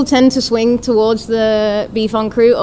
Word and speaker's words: en croûte: Help me pronounce that. – Beef en croûte. – Beef en croûte. – There you go. – Like beef en croûte. In French, yeah.